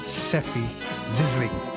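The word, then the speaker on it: Sefi Zisling: Matt rightly points out I've been mispronouncing that. Seffy Zizzling. That's what it is.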